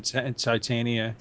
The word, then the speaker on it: Titania: How do you say that? Titania? Tatiana? – I've always said Titania. Yeah. – Titania. – Yeah, that's what I've said.